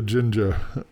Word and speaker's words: Jinja: Right, so Jinja. Did I say that right? – Yeah, that's right.